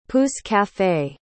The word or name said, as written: pousse-café